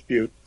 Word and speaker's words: Butte: But B-U-T-T-E, Butte, that's how I'm going to say it. Is someone going to tell me it's butt or what?